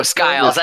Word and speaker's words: Skiles: Isn't it pronounced Skiles? – Oh, Skiles. I think it is Skiles.